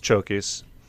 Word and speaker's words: Chokis: His name is his name is Chokis. – Chokis.